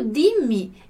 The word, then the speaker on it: dimmi: E o dimmi veio depois. E aí, quando acontece isso, o que fez aqui? Dobrou o M, né? Dimmi, dimmi. Sempre com a nossa pronúncia aqui, delle doppio consonante.